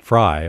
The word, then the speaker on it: Frey: Frey. Okay, so it's F-R-E-Y.